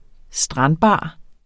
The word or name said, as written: strandbar